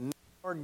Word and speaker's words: Nikanor: That's spelled N-I-K-A-N-O-R. The Nicanor Gate.